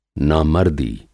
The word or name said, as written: नामर्दी